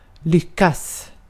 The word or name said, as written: lyckas